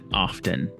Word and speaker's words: often: Often. – It is a weird word. – Because I don't usually pronounce the T.